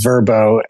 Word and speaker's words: Verbow: Well, let's start with, I think you're putting a little bit of a German bent on the pronunciation. Verboten and Verbo,